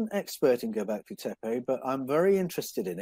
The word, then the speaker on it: Göbekli Tepe: And the next question then is, can share any thoughts on, I don't know how to pronounce this, Göbekli Tepe? Who made it? – Oh, yeah, Göbekli Tepe.